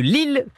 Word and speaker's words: Lille: Dans une minute, vous saurez pourquoi la ville de Lille porte vraiment bien son nom. Ah ouais Ouais, l'île L-I-2-L-E, quand on le prononce, ça sonne comme l'île L apostrophe I L-E. Et ce n'est pas un hasard,